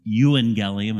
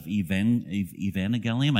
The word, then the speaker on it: euangelion: There's no V sound in Greek, and so that's why you pronounce it euangelium instead of evangelium.